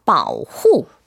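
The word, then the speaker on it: 바오후 (保护): 1번 바오후.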